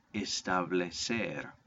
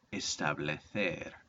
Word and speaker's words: establecer: Number two, establecer, or in Spain it would be pronounced establecer.